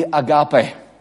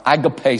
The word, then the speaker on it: agape: The word for love would be agape, or agape, some people pronounce it.